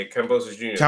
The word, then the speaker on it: Kambosos: Um, a lot of class and Gambosis, I, I, always say his name wrong. Gambosis, right? – Yeah, Kambosos Jr. Kambosos Jr. This is a mad fight, bro.